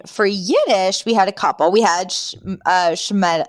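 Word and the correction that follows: shmata: Why am I saying it wrong? Shemata, which is rags or old ragged pieces of clothing or garments.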